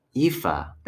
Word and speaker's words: Aoife: Aoife.